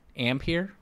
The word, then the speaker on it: Ampere: Let's move on, and we're going to talk about NVIDIA's next-gen Ampere. Is that how you say it? Ampere? – Yes.